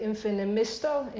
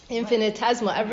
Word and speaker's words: infinitesimal: Infinimisto? Infinis. – Infinitesimal.